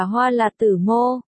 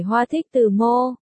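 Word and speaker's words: Hoa Lạt Tử Mô: Chú thích của tác giả, nước khuống, Hán Việt đọc là hoa là tử mô, chứ không phải hoa thích tử mô,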